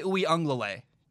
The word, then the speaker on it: Uiagalelei: Uiagalelei. DJ Uiagalelei. Got it right. I know that's how you say it. I nailed it.